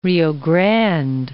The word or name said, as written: Rio Grande